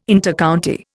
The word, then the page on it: Intercounty: Indian